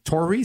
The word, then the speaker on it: Torre: Se escribe Torre con doble R. Tory se pronuncia, pero escribe Torre Washington.